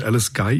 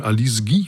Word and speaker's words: Alice Guy: Ich habe jetzt immer so das amerikanisch ausgesprochen, oder englisch, Alice Guy, ihren Namen, müsste auch sagen Alice Guy vielleicht, ne?